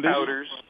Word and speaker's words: kabouters: Well, I thought there was an interesting story in your book about, I don't know if I'm pronouncing this, kabouters in Amsterdam in the 1970s. – Kabouters.